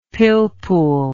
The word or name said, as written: pilpul